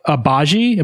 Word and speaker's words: Agbaji: And the details, as we learned later, the Jazz will receive Lauri Markkanen, Ochai Agbaji. Am I saying that right? – I believe so.